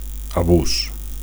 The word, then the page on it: abus: Catalan